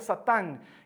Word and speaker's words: Satán: En hebreo se pronuncia Satán y significa adversario, enemigo, contrario, acusador.